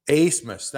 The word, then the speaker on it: Abmas: Asmus, thank you. – Because it's spelled A B M A S, but it's pronounced Asmus.